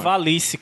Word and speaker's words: Ivalice: Não, não, não. Oi? Não? Pronuncia direito o nome do, do, do site, mano. Ivalice, cara. – Lice. – É, tipo, de Calice.